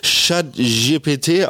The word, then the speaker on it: ChatGPT: Und zwar spricht man das Ganze jetzt ChatGPT aus, heißt das so viel wie die Katze, die gefurzt hat.